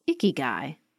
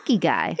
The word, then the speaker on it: ikigai: We're going to kick off today's episode with a Japanese concept I've recently discovered called ikigai. Have you heard of this, Beth? – Only from you, and I love the pronunciation. – Ikigai.